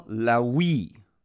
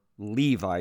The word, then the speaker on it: Levi: As he passed by, he saw Lawi, or Levi is the way we typically pronounce this in English now, the son of Alphaeus, sitting at the tax booth.